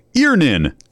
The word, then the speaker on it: Earnin: E-A-R-N-I-N is pronounced Earnin. I don't know how else you would pronounce it, but they really want it clear that that's how to pronounce it.